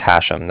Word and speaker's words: Hashem: My name is Hashem. That's spelled H-A-S-H-E-M, as in mother at the end.